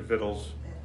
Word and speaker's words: victuals: So the people took, this word always gets me, I pronounce it victuals. Somebody else pronounces it vittles.